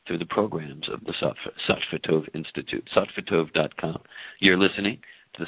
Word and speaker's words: satvitov: Satvitov, that's composed of two words from ancient wisdom traditions.